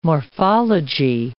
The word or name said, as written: morphology